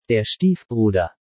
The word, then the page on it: Stiefbruder: German